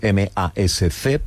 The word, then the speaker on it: MASC: Hemos dicho antes, hemos pronunciado el acrónimo MASC. – MASC. – Es sí. MASC, correcto.